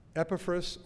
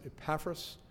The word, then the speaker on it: Epaphras: Epaphras, or as some pronounce it, Epaphras,